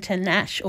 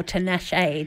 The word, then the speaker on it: Tinashe: That was No Broke Boys by Disco Lines and Tinashe, or Tinashe, depending on if you pronounce the E or you don't.